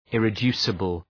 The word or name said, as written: irreducible